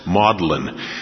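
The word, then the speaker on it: Magdalen: In fact, there are colleges in both Oxford and Cambridge named for her, although they're pronounced Maudlin. That's how her name came to be pronounced, Magdalene, Maudlin.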